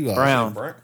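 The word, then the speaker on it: Braun: Christian Brown. Brown? – He Brown. – It's pronounced Brown.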